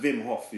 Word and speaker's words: Wim Hof: It's pronounced Wim Hof, is that, you know, how the Germans pronounce their W's and that.